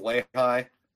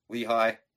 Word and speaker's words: Lehigh: So they lost to, I believe it's pronounced Lehigh. Lehigh. – Oh, Lehigh, yeah. – Lehigh.